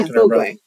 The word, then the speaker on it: Mogwai: Yeah, Mogwai.